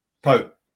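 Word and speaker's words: Pau: And by the way, is it Po or Po? – Po. Po. Mm. It's not Pow like Bao or the it's a French pronunciation, not a Chinese pronunciation.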